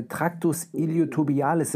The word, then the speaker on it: Tractus iliotibialis: Dieses Runners Knee, das ist ähm, äh, Tractus iliotobialis Syndrom. Ist das richtig ausgesprochen? Das ist richtig. Aber dieser, das können wir mal erklären, weil das ist eigentlich ganz spannend. Dieser Tractus iliotibialis oder IT-Band wird das äh, im Englischen bezeichnet.